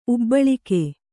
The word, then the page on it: ಉಬ್ಬಾಳಿಕೆ: ubbāḷike; Kannada